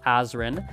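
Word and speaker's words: Azren: It is at Polyglot Azrin. That is spelled P-O-L-Y-G-L-O-T, A-Z or Z depending on your country, R-E-N.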